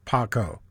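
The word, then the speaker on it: Paa Kow: Paco, P-A-A space K-O-W, pronounced Paco.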